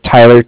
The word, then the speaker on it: Tyler: My name is Tyler, T-Y-L-E-R, and I'm calling with Cardiff. It's spelled C-A-R-D-I-F-F, as in Frank Frank.